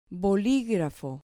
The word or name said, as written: boligrafo